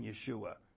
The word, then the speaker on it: Yeshua: Whatever name you like, because he said to me, people don't call him Yeshua. So, which is actually the Hebrew pronunciation of Jesus.